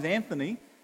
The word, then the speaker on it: Anthony: If your name has, like for instance, my name is Anthony, it has three syllables. Anthony, right? That's what you'd learn at school, Anthony. Three syllables.